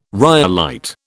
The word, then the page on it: rhyolite: British English